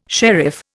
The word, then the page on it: sheriff: Indian